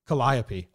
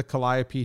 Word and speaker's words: calliope: Anyway, underneath the Calipio tooting goodbye crew world, over carpet clowns, spec girls, and an armless knife throwing. What? Calliope. I knew I was saying it wrong. Under the Calliope tooting goodbye crew world, over carpet clowns, spec girls, and an armless knife thrower, retreating to his tent between shows.